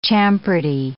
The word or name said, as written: champerty